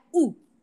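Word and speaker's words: Woo: Bueno, la familia, ojo, la familia Wu, que se escribe W-O-O y que en español sería W-U, en realidad Gu, pero ¿sabías tú que en coreano no se pronuncia, en coreano no sé por qué lo transliteraron así, pero se pronunciaría U? Sin la doble U.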